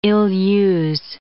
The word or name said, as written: ill-use